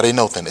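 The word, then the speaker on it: anothen (ἄνωθεν): That word from above, and we're going to put that in there in the Greek, that word from above, it's A-N-O-T-H-E-N. A-N-O-T-H-E-N. It's pronounced anothen. I apologize. It's anothen. It's not anothen. It's anothen. Anothen. From above in the Greek is the word anothen, A-N-O-T-H-E-N.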